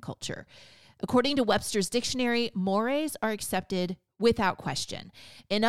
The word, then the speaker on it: mores: Now, the first category of presuppositions that Richards and O'Brien highlight in the book are mores. Now, that's spelled M-O-R-E-S, but it's pronounced mores.